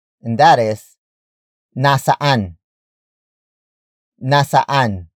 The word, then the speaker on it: nasaan: Word for word, it's nasaan ka na. Nasaan ka na. Nasaan ka na. The first word is nasaan. Nasaan. Nasaan. Nasaan is spelled as N-A-S-A-A-N.